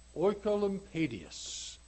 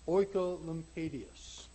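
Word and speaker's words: Oecolampadius: And a 47-year-old preacher with the impressive name of John Oikolampadius. And I want to spell his name because I'm going to refer to his name a number of times. His name is spelled O-E-C-O-L-A-M-P-A-D-I-U-S. O-E-C-O-L-A-M-P-A-D-I-U-S. Oikolampadius.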